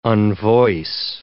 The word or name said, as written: unvoice